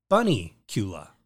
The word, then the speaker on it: Bunnicula: There's also, and I just always hope I never butcher this, but it's Bunnicula.